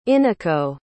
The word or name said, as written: eneco